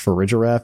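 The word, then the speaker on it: Farigiraf: But you can do it with Ferrigiraf because is that how it's pronounced? Ferrigiraf? It's so close to refrigerator.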